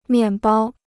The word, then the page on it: 面包: miàn bāo